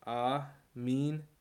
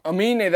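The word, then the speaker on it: Aminé: Buckhorn Bully says, Amine. – Amine, that's how you pronounce it. You were saying it another way, though. – Nah, bro. Run that shit back. I was saying Amine the whole time.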